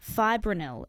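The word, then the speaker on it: fipronil: Nisha in Miller Miller, she says, is there a natural way of killing funnel ants instead of using insecticide containing fibrinil? I hope I've pronounced that correctly, Yvonne. – Yes.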